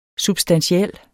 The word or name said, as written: substantiel